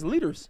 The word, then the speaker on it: Lueders: Carson Lead. I'm sorry. – Yo. How's my last name pronounced, boy? – Your last name is Lueders.